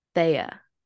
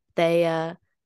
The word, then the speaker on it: Thea: I get Tia, Taya, Thea. Don't know where the Y comes from in that one. God knows what else. And I used to just let it slide for a while, especially if the person, like, if people like are, you know, natively speaking another language, especially Spanish, like they can call me Tia. I don't really care because you don't pronounce H's in Spanish. And that's that's like where the beginning of my name comes from.